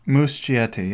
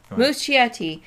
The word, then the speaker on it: Muschietti: I'm going to butcher the name, but the director's name is Andreas Muschietti. – Muschietti, yep. – Muschietti.